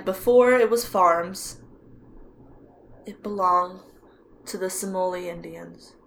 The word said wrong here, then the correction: Seminole: Simoli? Isn't it like mm-hmm. Seminole? – Seminole? I got into my own head about saying it right because I can't spell it right. – And it's S-E-M-I-N-O-L-E, correct? – Yeah, Seminole?